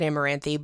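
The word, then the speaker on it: Amaranthe: I'm pretty sure they pronounce it Amaranthi, but we're just going to call it that for fun.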